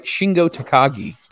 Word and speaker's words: Shingo Takagi: He dethroned, and I'm going to say this, and I hope I say it right, Shingo Takagi. Did I say that right? – Yep.